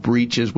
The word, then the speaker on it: breeches: It's spelled breeches. We would say breeches.